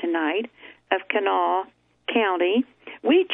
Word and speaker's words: Kanawha: But we, like the natives, insist the river, county, and state forest are all pronounced Kanawha.